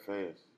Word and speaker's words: Faiyaz: Brent Faiyaz.